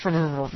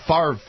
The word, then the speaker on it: Favre: It's 200 people he pardoned, which, you know, And why did the Favre family, why do they pronounce it backwards? It's spelled Favre. F-A-V-R-E. – Because we don't want to sound French.